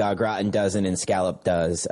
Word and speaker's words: au gratin: So my final pick here, the classic potatoes au gratin.